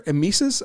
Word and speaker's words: emesis: Multiple episodes of emesis occur. Emesis? I'm not saying that right. – Emesis. Emesis. Vomiting.